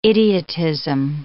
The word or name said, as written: idiotism